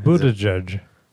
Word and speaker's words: Buttigieg: Pete Buttigieg. – Is he? Well, how do you say his name? – Buttigieg.